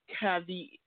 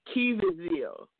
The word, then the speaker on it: Caviezel: It's Jim Caviezel. Caviezel. – That's right. That's right. I still can't pronounce it. – It's Caviezel.